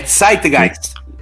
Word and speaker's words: Zeitgeist: onde aquilo que os alemães chamam de Zeitgeist, não fala alemão não, hein? tem que pronunciar de... É Zeitgeist. Zeitgeist Zeitgeist, é isso.